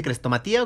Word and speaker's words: crestomatía: Digo, perdóname que, te interrumpa, pero tú te dedicas a, hacer las cresto, crestomatias. ¿Cómo se dice? ¿Crestomatía o crestomatias o...? – Es crestomatía.